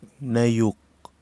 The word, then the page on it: nayouk: Kwamera